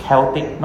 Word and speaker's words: Celtic: Okay, it's pronounced Celtic, not Celtic. Okay, Celtic is a basketball team. Okay, so it's Celtic.